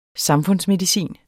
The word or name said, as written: samfundsmedicin